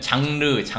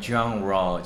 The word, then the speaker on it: genre: 근데 꼭 이렇게 genre라는 말 쓰고 싶으면은 아, 이미 느끼셨겠지만 우리 쓰는 발음 한국에서 쓰이는 음. 발음하고 많이 네, 달라요. 우리는 장르, 장르 그러죠. 근데 이렇게 우리 하면 거의 genre, 알아들어 모를 거예요. 네, genre 정도. 우리는 genre, genre.